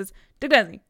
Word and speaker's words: Chittagong: I don't think we get a name for this world, but the city that he eventually ends up in is called Chittagong, I think. Is that how it's yeah. pronounced? If you were listening to the audiobook like I was, it's Chittagong.